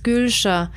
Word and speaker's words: Gülsha: Weil mein Vorname wird im Pass mit GY geschrieben, nicht mit GÜ. – Mm. – Und da meinte ich so, aha, das ist Gülscher.